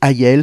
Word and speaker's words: Hayel: Tu retrouves plus d'infos sur les réseaux sociaux de Ayel. Cela s'écrit H-A-Y-E-L.